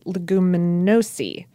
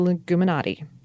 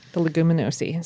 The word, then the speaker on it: Leguminosae: Anyway, but back in the day, the Fabaceae family was called the Leguminosae, not the Leguminati. The Leguminosae.